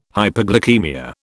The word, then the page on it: hyperglycaemia: British English